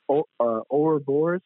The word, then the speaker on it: Ouroboros: Ouroboros? – Ouroboros.